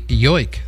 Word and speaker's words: joik: That fellow by the name of Vimi, W-I-M-M-E. I guess it's pronounced Vimi. And it's shamanistic chant meets modern electronics. Finnish Sami Joik singer is what he is. Joik, I hope I pronounced that right, spelled Y-O-I-K.